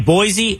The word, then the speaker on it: Boise: Boise? – Boise. – I say Boise. – Boise. Yeah, Boise. – That's what the locals call it. But I was in Boise.